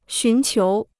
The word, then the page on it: 寻求: xún qiú